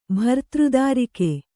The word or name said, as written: ಭರ್ತೃದಾರಿಕೆ